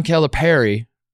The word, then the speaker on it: Calipari: Uh, we have been doing John Calipari.